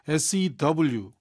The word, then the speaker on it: sew: 이제 똑같은 발음이에요. S-E-W. 근데 S-E-W랑 S-O-W가 똑같은 발음인 걸 우리 애청자는 다 외워주셔야 된다 이거예요.